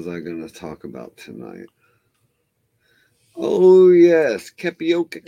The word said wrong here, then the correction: Maricopa: County, Arizona. – Maricopa. – That one, too. – That's what... Pronounce it right, you idiot.